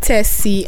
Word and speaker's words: Tessy: My name is Tessy and it's spelled as T-E-S-S-Y.